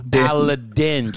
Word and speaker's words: Paladin: Actually, I want to talk about the Fairway Paladin really quick, if I'm pronouncing that correct, because that one, like... – Paladin.